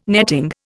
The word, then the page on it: netting: Indian